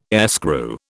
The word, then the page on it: airscrew: British English